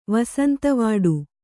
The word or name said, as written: ವಸಂತವಾಡು